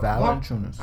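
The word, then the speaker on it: Valančiūnas: Is it Villachulis? How you say his name? – Valanciunas? – Yeah. But Valanciunas.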